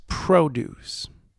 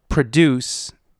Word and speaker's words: produce: The farm was cultivated to produce produce. The heteronyms in this sentence is produce and produce, both spelled P-R-O-D-U-C-E, but pronounced differently and with different meanings.